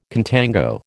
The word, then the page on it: contango: American English